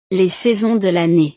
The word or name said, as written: les saisons de lannee